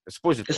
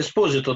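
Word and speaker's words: Esposito: Правильно я ударение ставлю, да? Эспозито. Эспозито, да. да.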